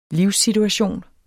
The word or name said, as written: livssituation